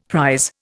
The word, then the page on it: prise: Indian